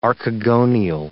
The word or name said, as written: archegonial